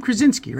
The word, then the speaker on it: Krasinski: How do you say his name? Krasinski, right? – Yeah. – I was going to say Krakowski. I don't know why. It's Krasinski.